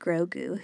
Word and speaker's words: Grogu: Um, and I, I kind of like, of course, I love Grogu.